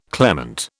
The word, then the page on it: clement: British English